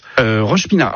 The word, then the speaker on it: Roche-Pinard: Vous changez de quartier, vous allez à Roche-Pinard. C'est bien comme ça qu'on prononce. – Roche-Pinard.